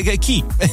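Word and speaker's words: Ké: Key con Strange World, no Key nel senso chi è, si chiama proprio Key, scritto K-E con l'accento, quindi è Key, basta.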